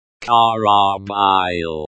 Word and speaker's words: корабль: The word karabl, pronounced karabil, means ship in Russian.